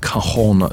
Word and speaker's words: cajon: It's called a cajon drum, C-A-J-O-N, correctly pronounced cajon. Not to be confused with cajones, which is something completely different.